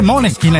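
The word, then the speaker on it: Måneskin: Io fossi nei Maneskin, che poi si dice Moneskin, hai detto prima. – Eh, mi hanno detto che si legge Moneskin perché c'è un simbolino, un cerchietto ah.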